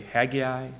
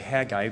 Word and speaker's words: Haggai: Some people say Haggai, some people say Haggai. I probably will say it both ways today, but I try to say Haggai because I think that's how it's to be pronounced.